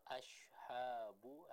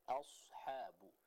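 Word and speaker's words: ashabu (أصحاب): Contoh ashabu, nah, ini kurang tepat. Yang tepat ashabu. Walladzina kafaru biayatina hum ashabul mash'amah, seperti itu.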